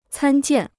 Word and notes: 参见: cān jiàn

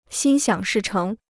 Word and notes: 心想事成: xīn xiǎng shì chéng

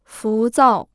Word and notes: 浮躁: fú zào

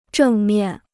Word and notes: 正面: zhèng miàn

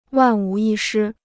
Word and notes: 万无一失: wàn wú yī shī

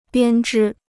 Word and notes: 编织: biān zhī

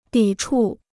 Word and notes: 抵触: dǐ chù

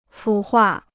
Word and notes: 腐化: fǔ huà

